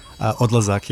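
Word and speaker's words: Odlazak: Am I pronouncing it right? – Odd Lazak, yeah, it means departure in Serbian.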